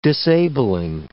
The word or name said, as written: disabling